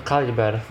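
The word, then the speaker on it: Calibre: Jeżeli ktoś zna angielski, nawet jeżeli wam się wydaje, że macie dobre źródła, to ja naprawdę polecam się do Kalibri i tak swoją drogą zapisać, do Kaliber właściwie, bo to tak się powinno wymawiać, bo to brytyjska pisownia.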